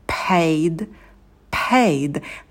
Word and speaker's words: paid: Pay, paid, paid. На звуке «п» добавляем придыхание. На дифтонге «a» держим оскал, рот открываем минимально, произносим дифтонг через улыбку, и в конце звук «д» не оглушается. Paid, paid. Дальше.